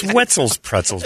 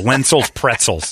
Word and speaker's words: Wetzel's Pretzels: Well, no, it rhymes with pretzel. The guy's like Wenzel's Pretzels. That'll be easy to say. – Pretzels. – There's no N in it, you idiot.